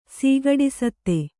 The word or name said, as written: ಸೀಗಡಿಸತ್ತೆ